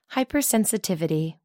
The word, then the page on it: hypersensitivity: American English